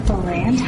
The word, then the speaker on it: Bolland: So we have Jessica Bolland. How do you say your last name? – Bolland. – Bolland. – Like Holland.